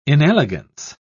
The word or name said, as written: inelegance